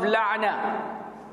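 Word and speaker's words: لعنة: You have to remember the definition of la'na. La'na. And you have to work on pronouncing the ayn.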